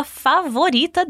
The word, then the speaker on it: favorita: Ela sabe que essa é a pipoca favorita dele. Em espanhol, como você diria sua favorita? Tu favorita. Atenção para a pronúncia do V, que em espanhol tem som de B.